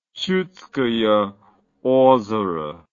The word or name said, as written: chudskoyeozero